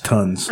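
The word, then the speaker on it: tonnes: How do you say it's a T-O-N-N-E-S? I know it's a measurement. I've seen it before. I don't know how I think it's still. I think it's still pronounced tons.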